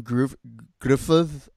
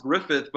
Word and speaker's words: Gruffudd: Tom Hardy, Jon Gruffudd at Griffith, it's pronounced Griffith. – fucking Welshman, man. Just speak English. – He does speak English. That's just the way he spells his name. It's pronounced Griffith, but that's just the way he spells it.